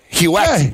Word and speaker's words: Huet: Remember Cristobal Huey? Huet. – Huet.